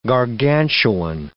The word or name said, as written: gargantuan